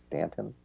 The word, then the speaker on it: Staunton: although it's spelled looks like Staunton, but it's pronounced Stanton.